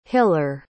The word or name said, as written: hiller